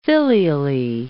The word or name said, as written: filially